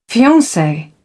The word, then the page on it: fiance: British English